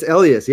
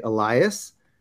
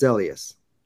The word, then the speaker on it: Elias: It's Elias. Yeah, a lot of people see it and they say Elias, but it's Elias.